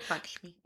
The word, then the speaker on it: Lakshmi: Parvati is the Hindu mother goddess of love, fertility, and devotion, along with Lakshmi, I think I'm saying that right? – Lakshmi.